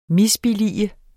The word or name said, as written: MISBILLIGEDE